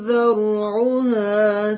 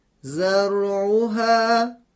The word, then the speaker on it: ذرعها: اگر این دو کلمه با حرف زا تلفظ بشه قطعا تلفظ ما غلط هست. بشنوید به چه صورتی خواهد شد. – زرعها زراعا. – خب این تلفظ تلفظ غلط است، چون ما حرف زا رو به جای دال تلفظ کردیم،